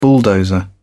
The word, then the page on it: bulldozer: British English